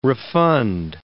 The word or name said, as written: refund